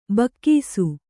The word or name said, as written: ಭಾಗಸ